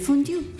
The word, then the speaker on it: fondue: Hay que poner la boca para decir U, pero decir I. – Okay. – Entonces, una sola vocal al final, fondue. Pones para U y decís y pronuncias la I.